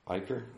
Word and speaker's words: Icher: Um, the European Solheim Cup team captains picks were Catriona Matthew, Caroline Hedwall, Caroline Caroline Masson, and Karine Icher. – Eicher. Oh, I'm going to take place of James this week for bad pronunciations. Eicher.